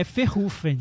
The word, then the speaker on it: Verhoeven: A gente aprendeu a pronunciar. É Verhoeven. Verhoeven, como se fosse ferrugem. Verhoeven. Porque ele é holandês, tá, gente?